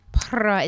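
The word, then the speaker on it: Pará: Para. Is there a J in it? – It's P-A-R-A. Oh. There's a little thingy over the A. There's no rolling of R's necessary.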